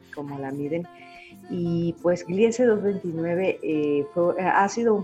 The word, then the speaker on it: Gliese: Ahora vamos a, cuéntame, y aquí me vas a ayudar a, pronunciar porque es Gliese, o cómo se pronuncia. – Interesantemente, se dice Gliese 229. Gliese. Es Gliese, es, el código de una estrella enana marrón.